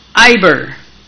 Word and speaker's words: Eber: That the proper pronunciation of Eber is Iber, A-Y-B-E-R.